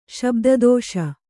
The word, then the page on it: ಶಬ್ದದೋಷ: kannada